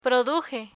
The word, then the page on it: produje: Spanish